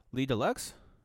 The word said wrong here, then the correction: Ledoux: It's not deluxe. It's D-E-U-X, bro. It's French. Le I think Dux? It's Ledoux. – Ledoux?